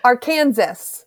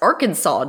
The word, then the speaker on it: Arkansas: No, because Kansas actually phonetically makes sense. Arkansas doesn't.